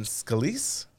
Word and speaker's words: Scalise: So the two individuals that are in the running right now are Jordan and Scalise.